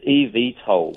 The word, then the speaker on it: eVTOL: Are they eVTOL? How do you pronounce the name of them? – It's eVTOL. – eVTOL.